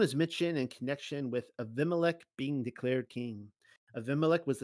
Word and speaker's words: Abimelech: And all the men of Shechem gathered together in all the house of Milo and went and made Abimelech, He would be pronounced Abimelech in the English, and made Abimelech king by the plain of the pillar that was to Shechem.